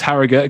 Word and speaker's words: Harrogate: it, it is Harrogate. That's the, the proper note to pronounce it. – All right, says the Yorkshire man. It's Harrogate.